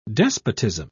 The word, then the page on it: despotism: British English